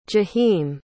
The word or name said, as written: jaheem